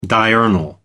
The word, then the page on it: diurnal: American English